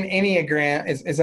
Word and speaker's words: Enneagram: Here's the nine Enneagram.